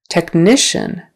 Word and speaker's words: technician: Technician. Technician. Again on the second syllable.